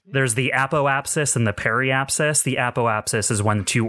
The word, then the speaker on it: periapsis: It's periapsis.